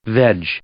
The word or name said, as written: veg